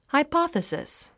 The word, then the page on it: hypothesis: haipάθəsis; ハイパサスィス